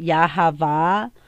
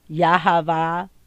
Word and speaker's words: Yahava: Now, Hebrew sound for letter, and then it says sounds like, and you know, some people, like I said, say Yahweh. I pronounce it Yahava. In English, they call him Jehovah.